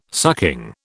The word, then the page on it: sucking: British English